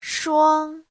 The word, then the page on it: shuang: shuang1